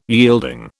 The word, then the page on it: yielding: British English